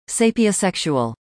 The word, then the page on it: Sapiosexual: American English